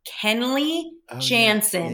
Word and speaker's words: Kenley Jansen: His name is pronounced Kenley Jansen.